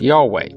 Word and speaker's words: Yahweh: It is almost certain that the name was originally pronounced Yahweh, but this pronunciation was not quite clear when the Masoretes added vowels to the Hebrew text.